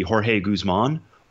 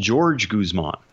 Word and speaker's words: Jorge Guzman: You know, I'll be the first to admit I'm not always great with pronunciations, but the man previously we believed to be Jorge Guzman wants to be called George Guzman. – Okay. – That's how it's said.